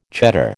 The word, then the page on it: Cheddar: American English